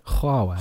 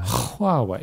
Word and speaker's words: Huawei: Now he's got his Huawei. – The Android. – And that is how you pronounce it, by the way. – Huawei. – Huawei. If anyone tries to tell you it's not, they're wrong.